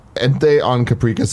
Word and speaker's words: Caprica: You said Caprica, which I like. It's like paprika.